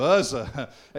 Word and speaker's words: Uzzah: You get to pronounce it like I do. Uzzah.